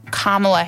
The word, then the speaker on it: Kamala: including a tutorial on how to pronounce Kamala Harris's name correctly.